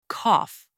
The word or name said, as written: cough